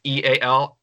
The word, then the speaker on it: EAL: You guys been pronouncing my name incorrectly for a while now. I've been pronouncing it because it's my initials. It's E-A-L are my initials. So that's how I've been pronouncing it. E-A-L.